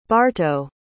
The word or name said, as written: barto